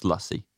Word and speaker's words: lassi: Told these guys it's pronounced Lussie.